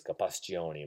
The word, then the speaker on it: Gapastione: and my name is Gapastione. Remember, so every vowel in the Italian pronunciation G A P A S T I O N E.